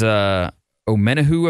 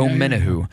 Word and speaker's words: Omenihu: Yeah, it's Omenihu, I believe. – Oh, yes, yeah. – Omenihu.